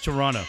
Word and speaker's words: Toronto: Pronouncing Toronto the right way? – No, I got in trouble. – Okay. – Again today. No, you don't say that last T.